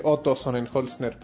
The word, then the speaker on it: Sonnenholzner: Ahí, y solo quería decir Otto Sonnenholzner para que vean cómo lo pronuncio bien, como un alemán cualquiera.